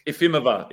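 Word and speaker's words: Jefimova: Like Ifimova. Ifimova. – Is that how you say it? – Yeah. – Or is the J silent? Is that a silent J? – Yeah, silent.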